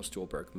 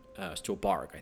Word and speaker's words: Stuhlbarg: Anyway, so I guess I understand why Michael Stuhlbarg might have been, Stuhlbarg, I think is actually how I pronounce it, why he may have been shut out from the nominations.